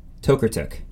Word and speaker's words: Tokarczuk: My next one is Drive Your Plow Over the Bones of the Dead by Olga Tokertuk. No, Tokertuk. Yes, that's the right one.